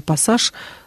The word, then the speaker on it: пассаж: Лев Залмунович нам пишет: в слове «пассажир» «с» растягивается, а в слове «пассаж» слышно два «с». – А вот орфоэпический словарь русского языка Ирина Леонидовна Родниченко говорит о том, что и в первом, и во втором случае можно произносить, а можно не произносить долгий «с».